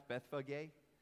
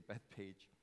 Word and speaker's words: Bethphage: He's coming from Bethphage, which they pronounce Bethphage, but it's easier in English to say Bethpage, and Bethany.